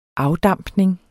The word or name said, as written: afdampning